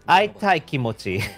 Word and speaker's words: Aitai Kimochi: So I want to give credit to Atai Mochi. Oh, sorry. Aitai Mochi. Aitai Kimochi. I can't always mispronounce her name. Aitai Kimochi for translating this one for us.